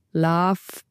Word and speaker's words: LOVB: Also sie wollen eigentlich, dass es halt wie die Liebe ausgesprochen wird, aber es ist halt wirklich Love. – Love. – Dieses B am Ende für Ball soll man eigentlich nicht aussprechen.